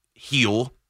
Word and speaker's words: Gil: He might not lead with Luis Gil. It's G-I-L, but it's pronounced H-E-E-L. That's why I've been calling him King of the Heel.